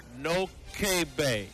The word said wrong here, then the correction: Noquebay: Nakabe. – That's not how it's spelled. – Noquebay, Nakabe.